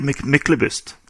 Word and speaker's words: Myklebust: How do you say it in Norwegian? – Usually Myklebust.